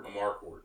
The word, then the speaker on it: Amarcord: Amarcord. Amarcord.